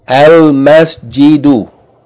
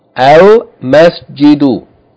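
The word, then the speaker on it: al-masjidu (المسجد): The next word, third word on line 2, al-masjidu, al-masjidu, meaning the masjid or the mosque. Again, meem is not a sun letter, therefore, yani, there's no doubling here.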